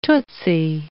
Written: tootsy

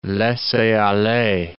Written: laisser-aller

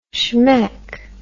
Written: shmeck

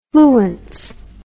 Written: fluence

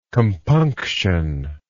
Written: compunctionless

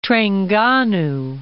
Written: Trengganu

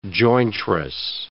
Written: jointress

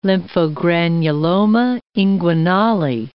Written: lymphogranuloma inguinale

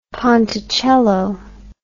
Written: ponticello

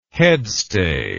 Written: headstay

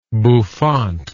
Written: bouffancy

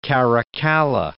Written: Caracalla

